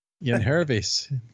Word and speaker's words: Yangervis: In that doubleheader, of course, we talked about everybody except Yenger Solarte. – Yen Hervis. – Yen Hervis. Okay, why is the G pronounced like an H?